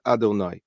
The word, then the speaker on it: Adonai: but it's pronounced differently than it appears before us. It is pronounced Adonai.